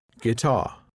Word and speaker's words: guitar: The silent U. The U isn't pronounced when it comes after a G and before a vowel. Guitar. Guidance, guest, guess, guard. For example, we were the only guests in the hotel.